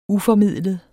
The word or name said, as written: uformidlet